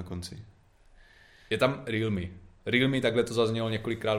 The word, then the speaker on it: Realme: Má to být Realme, no. Realme, je to okay. takhle jako Realme, takže krátce, na je.